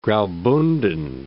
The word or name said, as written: Graubunden